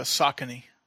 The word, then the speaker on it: Saucony: Saucony.